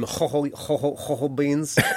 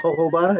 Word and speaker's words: jojoba: I know they have some ho-ho beans. – Jojoba, is that a thing? – You say it wrong. It's Jojoba.